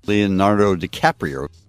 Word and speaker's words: Leonardo DiCaprio: Leonardo DiCaprio.